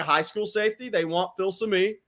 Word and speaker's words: Filsaime: They could take another high school safety, Xavier Filsaime. They could also. – I'm glad you're saying that name. So now I know how to pronounce it correctly because we've been butchering that joker for 2 weeks.